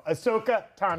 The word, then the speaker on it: Ahsoka Tano: You said Ashoka Tana. – Is it Ashoka? – Yeah, the H is silent. – It's Tano. – Tano. – Tano, my man. – Tano. Ahsoka Tano.